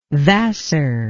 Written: Vassar

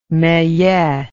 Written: meillet

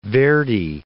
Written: Verdi

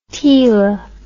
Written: tela

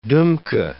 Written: dumka

